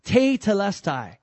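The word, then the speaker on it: tetelestai: The word is this, te telestai. T-E-T-E-L-M-R-X-T-P-S. No, I'm just kidding. It's a T-E-T-E-L-E-S-T-A-I. Te telestai is how you pronounce that.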